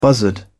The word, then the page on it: buzzard: British English